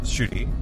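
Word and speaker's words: Ncuti: I'm talking about uh, Shuti, N-C-U-I, N-C-U-T-I is how you spell his name, but it's pronounced Shuti.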